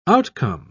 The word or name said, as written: outcome